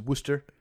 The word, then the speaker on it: Worcester: Everything in Massachusetts is spelled really long but pronounced really short. Like Worcestershire is Worcester.